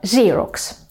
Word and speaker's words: Xerox: Да-да, именно Xerox, а не Xerox. Это имя бренда копировального аппарата, а также сам процесс копирования тоже будет Xerox. А все из-за того, что греческие слова, которые начинаются на X, произносятся именно вот так.